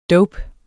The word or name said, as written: dope